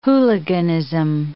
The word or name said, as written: hooliganism